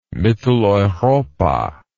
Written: mitteleuropa

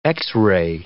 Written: Xray

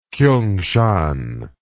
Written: kiungshan

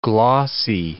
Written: glossy

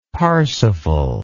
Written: parsifal

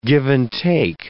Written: give-and-take

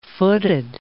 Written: footed

